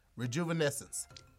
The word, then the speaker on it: rejuvenescence: Rejuvenescence. – Correct. – You gave him an easy word. – Hold on, first of all. – That was so easy. – Rejuvenescence. That's five syllables.